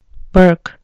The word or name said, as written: burke